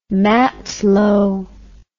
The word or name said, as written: matlow